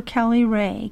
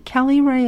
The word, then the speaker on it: Kelly Rae: I'm on Instagram at Ask Dr. Kelly Ray. Kelly Ray is spelled K-E-L-L-Y-R-A-E.